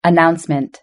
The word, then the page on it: announcement: British English